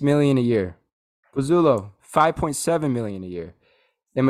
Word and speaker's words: Pozuelo: And then, forgive me if I'm saying this name wrong, but Pozuelo, one of their designated players. – Oh, Pozuelo.